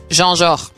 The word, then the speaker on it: Jean Jaurès: ¿Cómo se pronuncia? – ¿La trilingüe? – A ver. – En francés es Jean-Georges. – Jean-Georges. – Como Boulogne-sur-Mer.